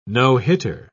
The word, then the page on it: no-hitter: British English